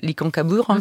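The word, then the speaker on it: Licancabur: l'Icancabur, c'est comme ça que l'on prononce, donc à 6000 mètres d'altitude, à la frontière entre la Bolivie et le Chili.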